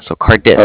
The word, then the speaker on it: Cardiff: It's Bank of Cardiff. Sorry, it sounds kind of funny over the phone. It's spelled C-A-R-D-I-F-F, like different, so Cardiff.